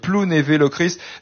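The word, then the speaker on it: Plounévez-Lochrist: J'ai fait attention, j'ai à peu près prononcé correctement, parce qu'il y a un Z à la fin, mais on dit Plounévé-Locrist.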